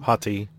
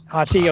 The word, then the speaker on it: Hati: And we have, from pictures, we have Shin Hati. I think that's how you say her name. – Hati. – Hati, okay, thank you.